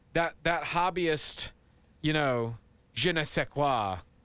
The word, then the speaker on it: je ne sais quoi: Hang on, let me pronounce that correctly. The je ne sais quoi. – Yep. Oh, there you go. Spot on. – Je ne sais quoi. Is that it? – Yeah, something like that.